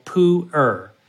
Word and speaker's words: pu'er: Now, what if you go to the far extreme, the most oxidized tea you can imagine is also a fermented tea called pu'er.